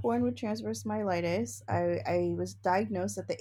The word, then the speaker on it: myelitis: So you have a condition that is called transverse myelitis. Did I say it correctly? And we went over myelitis. Yes, you did.